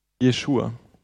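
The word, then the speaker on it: Jeshua (ישועה): Das hebräische Wort für Rettung ist hier Jeshua. Wird, es wird also ausgesprochen wie der wirkliche Name Jesus. Jesus heißt nämlich eigentlich Jeshua.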